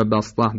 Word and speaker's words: بسطه: کسی که مد متصل و منفصل رو به توسط میخواند، یعنی داره طریق شاطبیه میخونه، اینجا نباید یبسطو و بسطه رو به صاد بخواند. هرچند جایزه، اما از طریق دیگر، از طریق شاطبیه فقط سین. یبسطو در بقره و بسطه در اعراف فقط به سین تلفظ میشه.